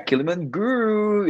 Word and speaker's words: Kiliman Guru: Yeah, Kiliman Guru. Yeah, you gotta say it with more energy.